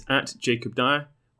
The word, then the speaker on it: Jacob Dyer: Okay, so if you want to follow me, my personal Twitter is @JacobDyer. Which is spelled like Jacob, and then the surname is D-Y-E-R. Some people had trouble with my surname.